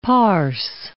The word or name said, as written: parse